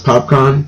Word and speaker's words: Popcaan: Yeah, again, I, I could be pronouncing his name wrong, but I think it's pronounced popcorn. Okay. P O P C A A N.